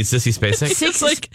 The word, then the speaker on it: Sissy Spacek: And Sissick Spacey plays her mother. – You mean Sissy Spacek? – Sissick Spacey, roll call.